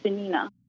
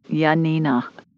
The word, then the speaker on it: Yanina: Her name's Janina. – Say it again. – Janina? – Spell it for me. – Y-A-N-I-N-A. – Okay. Janina.